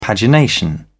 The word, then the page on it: pagination: British English